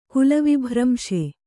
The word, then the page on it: ಕುಲವಿಭ್ರಂಶೆ: Kannada